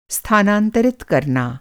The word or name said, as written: स्थानांतरित